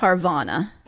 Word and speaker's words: Parvana: It's sounding like Parmona. – Yeah, Parvana. – So Parvona, maybe, is what they're saying? – Maybe. P-A-R-V-A-N-A, so.